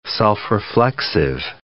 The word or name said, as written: self-reflexive